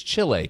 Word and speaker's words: Chile: Did I say that right? Chile. It's not Chile. It's Chile.